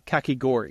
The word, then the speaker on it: kakigori: But in the Japan pavilion, it's called a kakigori. At least that's how we pronounce it.